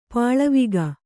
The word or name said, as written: ಪಾಲ್ವಕ್ಕಿ